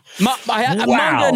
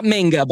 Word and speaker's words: manga: Manga, not manga, by the way. Manga is how a lot of people mispronounce it, but manga.